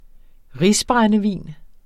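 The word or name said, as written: risbrændevin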